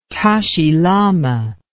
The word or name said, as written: Tashi Lama